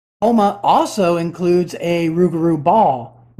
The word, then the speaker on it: Houma: There's a town, Houma. – Houma, Louisiana? – Is that pronounced? – Houma.